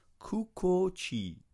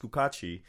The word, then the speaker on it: Cucatti: Kukachi. It's spelled C-U-C-A-T-T-I, but it's pronounced Kukachi.